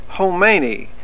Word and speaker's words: Khomeini: That country over there that got rid of their Shah and put in a hootenanny. – Sam, that's pronounced Khomeini, not hootenanny, and that's Iran, not Iran.